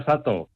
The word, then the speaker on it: Sato: ¿Cómo se pronuncia exactamente su nombre? – Pronuncia Sato. – Sato. – Pero en Japón le llamamos Ryu.